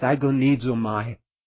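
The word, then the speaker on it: agonizomai (ἀγωνίζομαι): Would be pronounced agonizomai. We get our English word agonizing directly transliterated from that.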